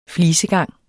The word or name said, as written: flisegang